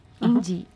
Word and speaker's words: Yndi: Y-N-D-I. Indie, uh-huh. Indie, ouais.